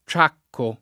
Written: ciacco

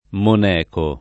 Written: moneco

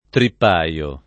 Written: trippaio

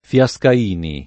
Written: Fiascaini

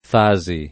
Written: Fasi

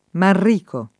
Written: Manrico

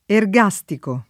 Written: ergastico

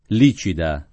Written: Licida

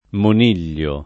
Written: moniglio

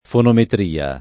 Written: fonometria

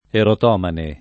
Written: erotomane

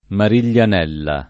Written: Mariglianella

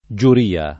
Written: giuria

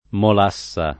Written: molassa

